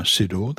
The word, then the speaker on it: Ciddor: And its author, Anna Sidor, that's spelled C-I-D-D-O-R, is Tamara's sister.